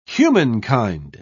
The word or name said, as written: humankind